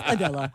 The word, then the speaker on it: Adela: And she's hanging out with her co-stars, all while this song, Ain't in LA, by this Slavic singer, Adela, I think is how you say her name. That's playing in the background. Apparently, that song is viral now. – Adela. – We're saying it wrong. It's Adela.